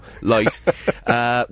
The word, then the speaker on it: Lighght: That's how we've been pronouncing it on the show, Lighght, with Topography of Data Erosion.